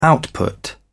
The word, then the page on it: output: British English